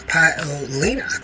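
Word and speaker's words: Paolini: Let's see, it's Jasmine Paolina. Paolina. That's how you pronounce her name.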